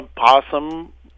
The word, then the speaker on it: opossum: It's often called a, uh, a possum, although it's spelled O-P O S S U M. It should be opossum, but we call it possum.